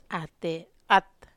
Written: at

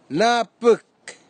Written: napik

